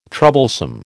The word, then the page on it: troublesome: American English